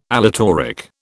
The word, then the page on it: aleatoric: British English